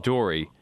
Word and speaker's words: Doris: And it's called D-O-R-I, right? – Yeah, D-O-R-I-S, but it's just pronounced Dory. It's D-O-R-I-S, new word, Metropolitan.